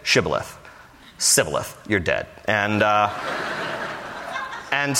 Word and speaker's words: Shibboleth: And if he said no, then they would say to him, then say, Shibboleth. And he would say, Sibboleth. For he could not pronounce it right.